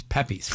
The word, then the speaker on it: Pepe's: See, it's Pepe's, Frank Pepe's. – You got it. – God, leave it to the talkbackers. You know, we were having fun here. – Yeah, you said it wrong.